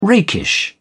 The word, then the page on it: rakish: British English